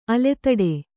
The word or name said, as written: ಅಲೆದಾಟ